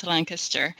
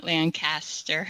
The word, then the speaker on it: Lancaster: Well, Lancaster, when I tell people that I'm from Lancaster, which is pronounced Lancaster, not Lancaster, sorry, that's a little thing that Lancastrians have. It needs to be said appropriately or the right way.